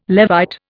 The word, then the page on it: Levite: Indian